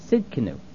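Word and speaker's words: Tsidkenu: The next word, Yehovah Tistkeno. – Sidkenu. – Right, that's what I said. And I guess that T is silent.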